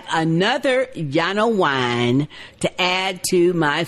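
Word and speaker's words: Llano: But it is Yano. That's L-L-A-N-O. Pronounced Yano Brewery, and it's Blush.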